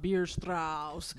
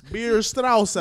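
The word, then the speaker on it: Bierstrasse: 133rd and 12th is spelled in a German fashion. It's B-I-E-R S-T-R-A-S-S-E. – Beer Strauss. – Beer Strauss.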